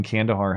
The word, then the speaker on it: Kandahar: I served around Kandahar. Did I say that right? – Yes, Kandahar, Afghanistan.